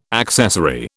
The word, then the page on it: accessory: British English